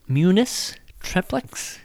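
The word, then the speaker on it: munus triplex: Priest is one of the three offices of Christ, munis triplex. Did I say that right, Rob? – Oh, yeah.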